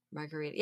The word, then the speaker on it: Margherita: Margherita. Yeah. Spelled differently, but yeah, it's pronounced the same.